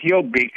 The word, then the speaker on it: Jobbik: The Jobbik Party? – Well, yeah, it pronounces Jobbik.